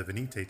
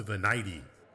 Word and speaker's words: Venite: You know, and being in Texas, and then before that, New Mexico, you know, I usually default to kind of using more of a Spanish pronunciation. Mm. And so, you know, the Venite is always the Venite to me.